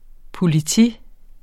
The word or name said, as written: POLITIET